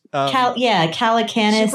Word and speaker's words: Calacanis: Calacanis? – Jason. – The famous Jason, yeah. – Caladocious. – Calacanis.